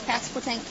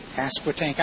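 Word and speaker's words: Pasquotank: Elizabeth City, North Carolina, which is Passquatank County. – Passquatank.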